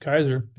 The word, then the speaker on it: Kizer: Is it Dr. Kizer? Is it just Kizer? – Just Kizer. Yep.